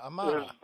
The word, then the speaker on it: Amana: Yeah, Amana. – Amana is the old one, but Americana is the one they're selling right now, and that's GE. – So you go in, you get an Amana rack.